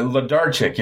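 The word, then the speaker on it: Wlodarczyk: Yeah, Wilodarczyk is the way that Entz with it, so with a silent W.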